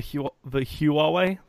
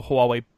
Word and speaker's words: Huawei: So that's the Huawei. That's not how you pronounce it. The Huawei Mate 10 Huawei.